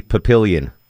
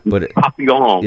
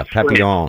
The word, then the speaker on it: Papillon: Longoria pronounces that movie Papillion. – Papillon. – Yeah, Papillon.